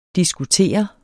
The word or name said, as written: diskutere